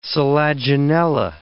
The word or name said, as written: selaginella